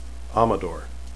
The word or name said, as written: amador